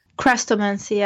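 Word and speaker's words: Chrestomanci: But if I had to sit here and name another Dinah Wynne Jones book, I would be like, Chestromancy? Is that a Dinah Wynne Jones book? – Crestomancy, yeah. – I pronounced it incorrectly, which shows you just how bad it is.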